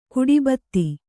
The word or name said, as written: ಗಡಿಭತ್ತ